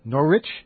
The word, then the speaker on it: Norwich: N-O-R-W-I-C-H, that's how it's spelled, and I pronounce that Norwich. And I was supposed to pronounce it N-O-R-R-I-C-H.